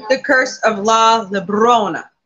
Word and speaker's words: Llorona: Well, it's two L's, so it makes a Y sound. So it's Llorona.